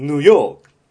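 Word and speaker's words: New York: ニューではなくて、ヌー。ヨークではなくて、ヨー。ヌヨー。そう、ヌヨーク。これがニューヨーク。じゃあ、ヤンキースは英語で何て言うかっていうと、これはカタカナ英語でそのまんま、ヤンキースで通用します。だからニューヨークヤンキースを英語で言うと、ヌヨーク・ヤンキース。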